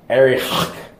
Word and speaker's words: Arioch: Now, the next person, Arioch, if I could pronounce his name in Hebrew like the experts can, it's like Arioch. It's something like that. It's like you're clearing your throat.